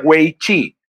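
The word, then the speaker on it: wei chi (危机): de hecho se pronuncia wei chi, y wei significa riesgo y chi significa oportunidad.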